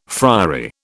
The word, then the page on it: friary: British English